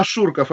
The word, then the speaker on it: Ашурков: Нет, я о другом, что Ашур, Ашурков, я правильно поставил ударение? А, а, Ашурков, Ашурков.